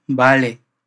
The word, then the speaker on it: vale: Ojo a esa V que aparece ahí al comienzo de la palabra vale. Hay muchos lugares en que la V se puede pronunciar como en portugués, pero los nativos van a pronunciarla mayormente como B.